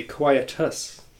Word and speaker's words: Quietus: Ender Lilies, Quieter of the Nights, gets best title for this show. – Even better, saying it correctly, Quietus.